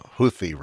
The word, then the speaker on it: Houthi: I believe it's pronounced uh, Houthi rebels.